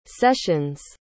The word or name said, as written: session's